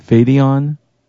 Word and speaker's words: Phiaton: P H I A T O N, Fadeon.